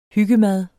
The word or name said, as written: hyggemad